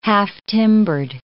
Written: half-timbered

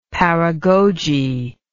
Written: paragoge

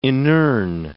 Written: inurn

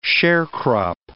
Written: sharecrop

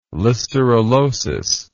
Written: listerellosis

